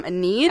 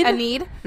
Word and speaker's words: Enid: My fun fact was that the fashions of, how do you say this girl's name? Enid? – Aneed. – Aneed.